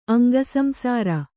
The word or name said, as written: ಅಂಗಸಂಸಾರಿ